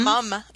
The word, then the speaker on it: mom: we spell it M-O-M, but we pronounce it M-U-M. So we like to walk the line between the UK and America, right? So it's like, we don't spell it the British way, but we pronounce it the British way and spell it the American way.